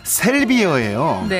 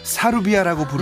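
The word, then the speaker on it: 샐비어: 정식 명칭은 셀비어예요. – 네. 이게 일본식 발음으로 많이 알려지다 보니까 사루비아라고 부르는 음... 분들이 참 음. 많으셨다고 알고 있습니다.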